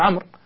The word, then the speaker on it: عمرو: مثلا مثل واو الجماعة، مثل الواو اللي في عمرو، ما حد ينطقها عمرو صح، ولكن يقولون عمرو، والواو يقولون للتفرقة بينه وبين عمر مثلا.